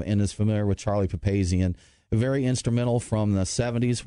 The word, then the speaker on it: Papazian: After 37 years, Charlie Papazian, I hope I pronounced that correct. – I believe it's correct. I believe it's Charlie Papazian.